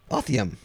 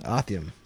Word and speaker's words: Othyem: Thusa, T-H-U-S-A. Tom Harris. All right, so that is 600 fines. Dwayne, please write me and tell me how I'm supposed to pronounce O-T-H-Y-E-M. – Let me see. It's Othium. – Othium? – Othium. – All right, that's right. I'm going with Othium until... – Or Othium. – Or I'm going with that until... Yeah, tell me otherwise.